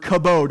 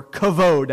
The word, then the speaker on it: kabod: Glory is the word kabod, or kavod, as it's variously pronounced.